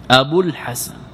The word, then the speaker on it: أبو الحسن: إذا قلت جاء أبو الحسن، أبو الحسن، أين الواو؟ هل تنطق بها؟ أبو الحسن، أبوك، الباء مضمومة، حينئذ قلت أبو الحسن، الواو ساكنة واللام من الحسن ساكنة، فالتقى ساكنة فحذفت،